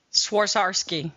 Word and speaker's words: Swarovski: Swarovski.